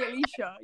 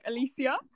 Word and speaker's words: Alicia: Everyone else calls me Alicia, yet you call me, like, Alicia.